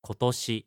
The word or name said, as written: kotoshi